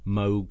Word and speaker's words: Moog: It was around that time that synthesizers first became affordable, because before that there was the big old Moog synthesizers and ARPs that were used by the techno bands. – Note that he says Moog. – I didn't know that. Yes, that's the on the muso correct pronunciation.